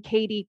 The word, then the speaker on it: Cady: It's Alison Cady Coaching.